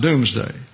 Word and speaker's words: Domesday: You have D-O-M-E-S-D-A-Y. It looks like domesday, pronounced doomsday. And it's corruption of the spelling D-O-O-M-S-D-A-Y, doomsday, the day of the final judgment.